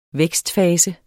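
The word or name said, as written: Vækstfase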